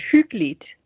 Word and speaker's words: hyggeligt: It's hyggeligt. It's spelled H-Y-G-G-E-L-I-G-T, and it's usually translated as cozy, but that doesn't really do it.